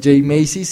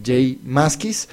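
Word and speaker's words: J Mascis: Ya me dijeron que corrigiera la pronunciación. No es Jay Maskis, sino Jay Maskis.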